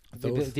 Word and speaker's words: Thoth: You know, but it's like T-H-O-T-H, not T-O-T-H.